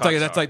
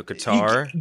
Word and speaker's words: Qatar: So it is. It's Qatar. That's like a mix between the both of them. – Qatar. Okay. – And then there's a second pronunciation. – So Qatar.